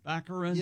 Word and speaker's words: Baccarin: Daredevil's girlfriend, Morena Baccarin? – Baccarin. – Yeah, I think that's what it is.